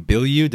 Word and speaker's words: Bilyeu: I'm here with Tom Bilyeu.